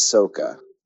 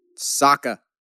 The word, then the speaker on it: Sokka: The other character I'll nominate from that show that I think is just as important as everybody else is Soka. Sokka. Not Soka. They look man. definitely Sokka. We're not going with the Shyamalan pronunciation here.